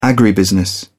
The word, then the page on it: agribusiness: British English